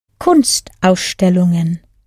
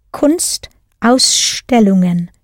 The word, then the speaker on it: Kunstausstellungen: Kunstausstellungen. It's an art in itself, pronouncing these compound German words, isn't it? The secret is to allow yourself gaps in between. Kunstausstellungen, Kunstausstellungen.